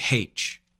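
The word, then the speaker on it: Aitch: they say H. But if you spell the way the English say it, the Americans say it, it's A-I-T-C-H-H. That is that artist's name.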